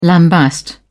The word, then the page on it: lambaste: British English